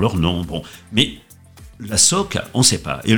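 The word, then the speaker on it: socca: Attention, on dit bien soca, comme tu l'as très bien dit d'ailleurs, et non soca, qui n'a aucun sens et qui est encore plus affreux à prononcer.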